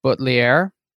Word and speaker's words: Buttliere: Let me see if I say it right. Buttliere. – Yeah, Buttliere. That's it.